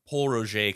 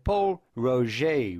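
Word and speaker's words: Pol Roger: It is a French company. Therefore, you must pronounce it Paul Roger, rather. Paul Roger, rather than Paul Roger.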